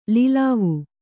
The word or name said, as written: ಲೋಲುವೆ